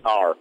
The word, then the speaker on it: Willner: My name is spelled with two L's, Willner, Richard Wilner.